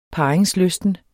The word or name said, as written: parringslysten